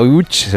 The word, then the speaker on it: Łódź: Pues vamos entonces a Łódź, si te parece, Enrique, para saber saber algo más de esta ciudad, aunque se pronuncia Łódź, se escribe Lodz, por si alguien lo busca.